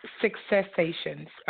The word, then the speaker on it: Successations: Is that how it's pronounced, woman of God? – It's Successations.